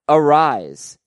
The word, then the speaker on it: Arias: He pronounces it Arise, no S sound on the end, like Luis Arias.